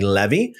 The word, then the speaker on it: Levi: The Levitical priests, the whole tribe of Levi, or Levi, actually the more accurate way to pronounce this would be Levi, shall not have a portion or inheritance with Israel.